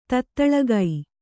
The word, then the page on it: ತತ್ತಳಗೈ: Kannada